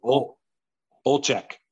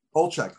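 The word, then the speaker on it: Olczyk: Is Eddie Olchek? – Olchek.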